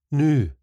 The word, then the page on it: noo: スコットランド英語